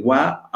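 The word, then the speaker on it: wa (は): Hemos aprendido cómo funciona el wa, aunque se escribe ha,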